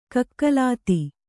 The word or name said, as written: ಕಕಲಾತಿ